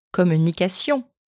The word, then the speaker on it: communication: It's C-I-O-N, and it's sion. And even in Portuguese, it becomes sao. So let's take the English word communication. It's the same exact ending in French, but it's just pronounced differently. And in French, it's communication.